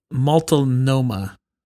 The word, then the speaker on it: Multnomah: It's pronounced Multnomah Falls.